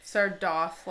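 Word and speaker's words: Sardothien: Sardoth, like cloth.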